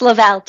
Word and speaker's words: Lovell: Lovell, just like love.